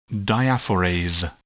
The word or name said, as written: diaphorase